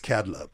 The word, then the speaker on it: Kadlub: It's Kadlub. – Kadlub, yeah. – K-A-D-L-U-B, so Kabuki is K-A-B.